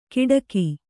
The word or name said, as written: ಕಿಟಕಿ